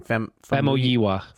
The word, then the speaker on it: Famuyiwa: Famuyiwa. – Famuyiwa.